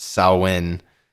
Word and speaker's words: Samhain: He mispronounced Samhain and thus betrayed himself a fool. It's pronounced Samhain. Don't ask me. Celtic languages are fucked.